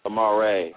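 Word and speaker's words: Amar'e: Amare.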